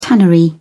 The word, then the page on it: tannery: British English